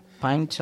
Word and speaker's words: Pint: que Magallanes va a participar en Pine, a ver si lo digo bien, of Science. – Pint of science.